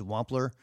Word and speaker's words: Wampler: I know what it's like whenever people call me Wampler, because it is Wampler, and it doesn't really hurt my feelings, but if it's New Neighbor, I'm sorry.